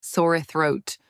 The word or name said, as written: sore throat